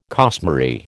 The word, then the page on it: costmary: American English